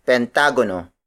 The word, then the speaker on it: pentagono: The next shape is pentagono. Pentagono. Pentagono. Pentagono is spelled as P-E-N-T-A-G-O-N-O. It means pentagon, a shape with five angles and five sides. Some Tagalog speakers may pronounce this word as pentagono.